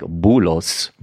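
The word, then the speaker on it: bulos (بولس): But today we are talking about Paul, or as we say in Arabic, bulos. – Bulos? – Bulos.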